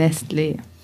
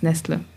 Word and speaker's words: Nestlé: In der Theorie würde der Akzent die Betonung erhöhen, also mehr Betonung auf dem E, aber ich finde so ein hartes, kurzes Nestle, das sagt schon genau unsere Emotionen dabei aus.